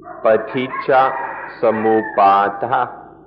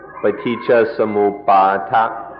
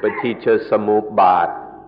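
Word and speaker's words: paṭicca samuppāda: The word is paticca samuppada. Paticca samuppada. In Thai, the pronunciation is a little shorter, paticca samuppada. But in the Pali, it's more like paticca samuppada.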